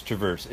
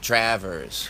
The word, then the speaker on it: Traverse: My grandmother pronounced it Travers. – Travers, okay. – So, my father was a traveling salesman that hated the way his name was pronounced. – Yeah. – So we changed it. – Yeah. – And I continue to change it. – Oh, yeah? So where are we at now with it? – Travers. – Travers? – Yeah, with a long A.